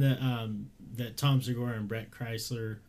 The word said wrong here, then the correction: Bert Kreischer: It's Bert Kreischer.